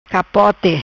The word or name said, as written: Capote